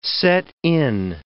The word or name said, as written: set-in